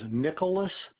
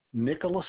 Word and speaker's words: Nickolas: My name is Nicholas Martin. Nicholas is spelled N-I-C-K-O-L-A-S, and Martin's M-A-R-T-I-N.